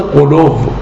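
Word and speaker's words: غلو: دوستان کلماتی مثل قلوف، قلوف، سموف، این کلمات رو در زبان فارسی به شکل قلو، سمو و علو تلفظ میکنن.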